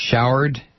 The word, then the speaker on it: Shourd: And you know the story of Sarah Shourd, I guess it's pronounced Shourd, Shourd, S-H-O-U-R-D, Shourd.